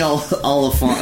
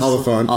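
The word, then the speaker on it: Olyphant: I'm gonna next week's special guest is old Timmy Oliphant. How do you pronounce Oliphant? – Oliphant.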